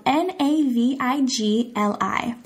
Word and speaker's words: Navigli: I think it's a street or an area called Navigli. That's spelled N A V I G L I. Navigli.